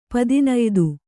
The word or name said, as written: ಪದಿನಯ್ದು